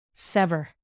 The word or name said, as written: sever